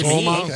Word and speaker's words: Somas: And Somas. Am I saying that right? Somas? – Sounds good to Somas.